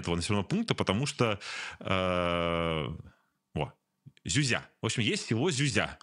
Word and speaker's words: Зюзя: Ну и они сделали репортаж из села Зюзя, именно так ставится ударение, которое типично российское загибающееся село.